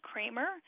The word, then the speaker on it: Kramer: You can find me there under my own name, Kirsten Kramer.